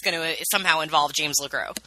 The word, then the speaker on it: LeGros: So, patron saint from the show, apparently, James LeGros. – Okay, yes. Well, here's the thing. I've always said James LeGros because I assume that you don't pronounce the S.